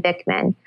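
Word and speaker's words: Bikman: And right now for the reset, one of the things I'm reading is called Why We Get Sick by Benjamin Bachman. How do you say his name? Bickman? Benjamin Bickman.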